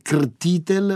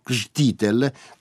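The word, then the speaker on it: Křtitel: Ed ora l'ultima novità discografica, un disco di L'Hyperion, sono canzoni di Václav Jan Krtitl del Tomášek, veramente difficile pronunciare il terzo nome perché è Krtitl, ma la R Krtitl,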